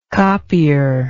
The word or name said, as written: copier